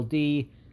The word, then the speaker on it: Macdonald: Google Norm Macdonald, and it's spelled Macdonald. By the way, the D after the C is not capitalized. Norm Macdonald, I should be saying. Whenever he was on Letterman really hit the Mac in Macdonald. He made sure to pronounce it, to overpronounce it, and make it a thing.